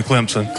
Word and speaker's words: Clemson: Tyler officially listed at 6'3", 306 in his third year out of Clemson or Clemson, depending on what part of South Carolina you are. – Definitely Clemson.